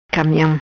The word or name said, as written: Camiam